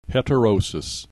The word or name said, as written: heterosis